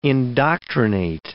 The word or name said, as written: indoctrinate